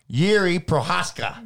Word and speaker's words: Jiří Procházka: Anyways, speaking about Pessy saying it, Jiří Procházka, that's how you say it.